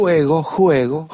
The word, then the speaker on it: Juego: Allora, con questo album che si intitola Juego, qual è la pronuncia esatta, maestro? – Juego.